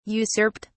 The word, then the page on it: usurped: British English